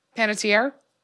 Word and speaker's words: Panettiere: How do you say it? – Panettiere.